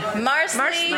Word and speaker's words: Marsley: Marsley. – Rhymes with parsley.